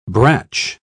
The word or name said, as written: brach